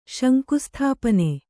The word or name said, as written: ಶಂಕುಸ್ಥಾಪನೆ